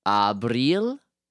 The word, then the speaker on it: Abril: Abril? How do you spell that? – A-B-R-I-L.